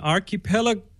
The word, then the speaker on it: archipelago: Well, Lofoten is an, what's the term, an archipelago, how do you pronounce that? – Archipelago.